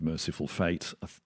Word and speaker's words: Mercyful Fate: I'm pronouncing it that weird way because it's not merciful with an I, it's mercyful with a Y.